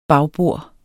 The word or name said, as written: bagbord